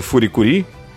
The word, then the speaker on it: FLCL: Я не знаю, есть ли у них для этого специальное слово, но, например, а, классика это фури-кури, FLCL. Э, э, э, почему FLCL, кстати, пишется? Потому что на японском это читается как фори, хори, как раз.